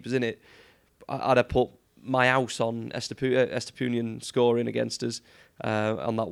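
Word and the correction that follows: Estupiñán: Hold on, what was that pronunciation again? – Estupinian. – Estupinian.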